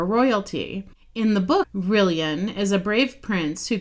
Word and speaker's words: Rillian: One of the names we are considering is the name Rylan, or Rillian. no, Rillian, pronounced like billion or million.